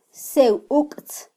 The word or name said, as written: sewuktz